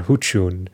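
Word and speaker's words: Xučyun: The area of Saucel Creek is most likely an area, a tribe called Huchun, spelled X-U-C, but the C has a little wedge over it, which is a ch sound, Y-U-N.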